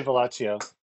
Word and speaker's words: Villaggio: No, I say Villaggio. – All right. Good to know. – So I'd say that's some more Italian pronunciation, right? The Chio. – I mean, that's kind of what I had always assumed.